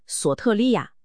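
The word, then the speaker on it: 索 特 利 亚 (σωτηρία): 这里有一个非常关键的词汇证据，他在原文中使用的" 得救" 这个词，它的希腊文发音大概是索特利亚。郝蒙博士指出，当我们纵观保罗所有的历史书信，会发现一个极其严格的词汇使用模式，也就是他使用词汇的某种固定习惯。 – 没错，每当保罗使用索特利亚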